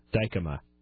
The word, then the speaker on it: Dykema: Dykema.